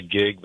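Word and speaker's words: Kieg: They pronounce it the Gig, but it's K-I-E-G, and it's in Thomaston, Maine.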